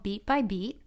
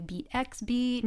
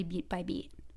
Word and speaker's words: Beet by Beet: A lot of people don't really know how to say it. Sometimes they say Beet X Beet, mm-hmm. or Beet Beet, like they're not really sure, but it's actually Beet by Beet.